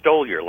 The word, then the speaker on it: Stoliar: Oh, Stoliar, like stole your time.